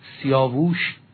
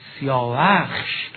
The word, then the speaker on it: سیاوخش: کیخسرو پسر سیاوخش، که عرض کردم سیاووش خطای مسلم است، زیرا سیاوخش درسته و تلفظ درست آن سیاوخشه.